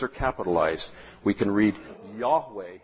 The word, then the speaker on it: Yahweh: And it's usually pronounced Yahweh.